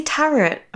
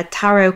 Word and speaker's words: tarot: Do you say tarot? – Yeah. – I say tarot. Am I saying it wrong? – I think so. Shall I Google it? Shall I Google it? – Yeah. How to pronounce that? – Pronunciation. – I don't want to say it wrong. – It's all right. It's like the third highest suggestion on Google. So is it really? – Yeah, you're not the only one. Yeah, it is a silent T at the end.